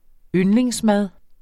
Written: yndlingsmad

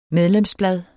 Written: medlemsblad

